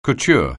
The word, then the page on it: couture: anglais